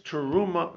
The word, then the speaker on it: Teruma: I'm not saying it right. Truma, chametz, Teruma. Is that the way to say it? – Yes.